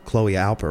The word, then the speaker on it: Chloe Alper: The beautiful Chloe Alper. Am I saying her name correctly? – Yeah, she's just stunning as well.